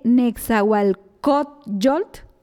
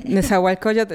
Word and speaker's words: Nezahualcóyotl: Ella viene de Nezahualcóyotl, ella me va a corregir, del Estado de México. Corríjame que si lo dije mal. – No, estuvo bien. Nezahualcóyotl, Estado de México.